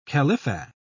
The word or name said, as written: khalifa